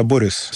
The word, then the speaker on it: Борис: Ну, да, я сказал не Борис, а Борис, с именем Борис. Здесь очень важно, а ударение в данном случае очень важно.